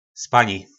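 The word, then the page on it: Spani: nářečí Po našimu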